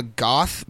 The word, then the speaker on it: Gauth: This one is, there's another one called a Goth, but it's spelled like G-A-U-T-H, and they're really, really nasty.